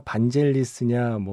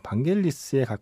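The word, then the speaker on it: Vangelis: 이게 반겔리스냐 반젤리스냐 뭐 부르는 사람에 따라 다른데 그리스 현지 발음으로는 반겔리스에 가깝다고 해요. 그래서 오늘은 반겔리스라고 발음하겠습니다.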